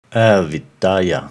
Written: Avidya

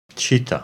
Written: Chitta

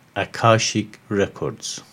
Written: Akashic Records

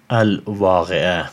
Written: Al-Waqi'ah

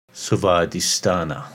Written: Svadhisthana

